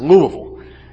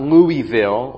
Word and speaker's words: Louisville: It's the Southern Baptist Theological Seminary, and it is pronounced Louisville, not Louisville or Louisville or anything like that. Louisville.